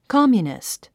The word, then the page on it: communist: kάmjunist; コォミュニスト